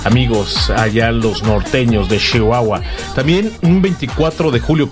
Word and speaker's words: Chihuahua: Saludos a toda la gente, a todos los amigos, hermanos que conocí por allá en Ciudad Juárez, Chihuahua. Chihuahua, así dicen ellos, allá es Chihuahua. Ellos te pronuncian más, muy marcada la She.